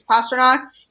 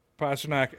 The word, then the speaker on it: Pastrnak: because, I mean, well, I think the nickname kind of helps with that too, with calling him Pasta so that kind of naturally flows with his actual name, which is Pastrnak. And, you know, all the commentators say it like that. So I think him having that nickname of Pasta yeah. exists for a reason. And, and part of that is to help people actually pronounce his name right because that is how you say his name.